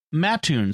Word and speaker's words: Mattoon: As a note for your broadcast, Mattoon is locally pronounced Mattoon, sounding closer to the boy's name instead of sounding like a spittoon.